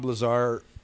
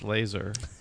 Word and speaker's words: Lazar: Um, and and it's like when when you hear like Bob Lazar talk about reverse engineering UFOs. I think it's pronounced laser.